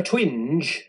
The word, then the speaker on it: twinge: Not a twinge. – Yeah. – Yeah. Sorry, to rhyme with fringe.